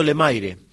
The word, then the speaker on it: Lemaire: Los Chajacitos Lemaire. Se escribe Lemaire porque es un apellido francés, mi abuela era Lemaire, y ellos eran pescadores, siempre se dedicaron a la pesca y todo, son muy conocidos.